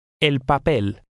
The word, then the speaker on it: el papel: In number 8, the stress is again on the last syllable. Read number 8. – El papel.